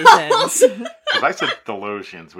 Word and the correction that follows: Thasians: which is not. – And Thalesians, it. Thasians. – That's it. – The Thasians. Oh, it's definitely more syllables. – Okay, so there's no L, the Thasians. – There's no, it's just Thasians.